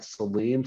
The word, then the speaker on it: Salim: You can follow me at Salim Sports. My name is spelled S-N-Sam, A-L-I-M, as in Mary, _BG Hoops.